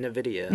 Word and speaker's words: NVIDIA: Another one from The Verge, where, and I'm going to say this wrong, I'm sure, NVIDIA? – How do you say it? NVIDIA. – NVIDIA.